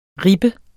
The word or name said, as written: ribbe